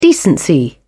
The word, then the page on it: decency: British English